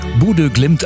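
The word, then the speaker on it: Bodø/Glimt: Ja, aber eigentlich spricht man die ja Bodo Glimt aus. Das Sensationsteam aus Norwegen.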